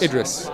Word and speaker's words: Idris: Idris.